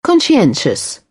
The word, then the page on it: conscientious: British English; ɒ